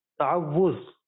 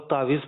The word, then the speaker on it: تعوذ: تعوذ، بعض لوگ تعویذ پڑھ رہے ہوتے ہیں، بعض تعزیہ پڑھ رہے ہوتے ہیں، بعض تعوذ پڑھ رہے ہوتے ہیں، تلفظ ہے تعوذ.